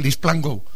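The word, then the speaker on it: LeasePlan: Pues se lee así, ¿no? Lease Plango. Se lee, digo, tú puedes pronunciar como quieras, pero le dije, se lee Lisplango.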